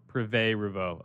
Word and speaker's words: Privé Revaux: Privé Revaux.